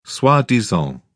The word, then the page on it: soi-disant: British English